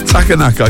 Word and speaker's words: Takanaka: Masayoshi Tanaka. Takanaka, I should have practiced that beforehand.